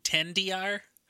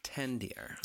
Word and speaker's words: XDR: And just so you know, that is pronounced 10DR. – 10DR. I see.